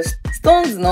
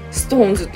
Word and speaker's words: SixTONES: シックスって書いてトーンでストーンズって読むん